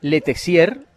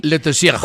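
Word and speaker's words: Letexier: Mire, a propósito de sueldos y de pagos, le leí a don José Borda la plata que se llevó François Letessier, ¿cierto? Se pronuncia así. – Letessier.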